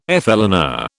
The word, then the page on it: flâneur: British English